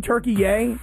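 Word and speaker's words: Türkiye: Listen, I liked saying Turkey Yay because I found this interesting that there was this new pronunciation of the country Turkey.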